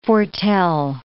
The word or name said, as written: foretell